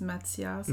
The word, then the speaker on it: Matthias: In French, it's Matthias. – Matthias in French.